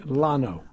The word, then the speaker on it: Lano: As we gently, it's Lanau, is that correct? – No. – How do you say it? – Lano. – Wow. – It was spelled L-A-N-O, Lano.